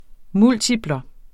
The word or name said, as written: multiplum